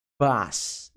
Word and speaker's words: boss: O, a letra O, muitas vezes em inglês, soa como A. Então fica ba, boss, boss.